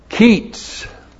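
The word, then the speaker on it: qits: We have the hiphil imperative of the verb keets, plus the masculine plural adjective shikor. In the plural, it's shikorim, plus the conjunction vav prefix to the cal imperative of baka. Keets. Q long I T S. Keets.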